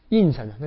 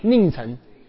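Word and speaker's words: 佞臣: 印臣，那个字怎么读？一个人字边一个吏什么？令，印，哎，等一下，令臣。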